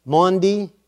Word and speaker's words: Maundy: Maundy Thursday. And that is spelled M-A-U-N-D-Y.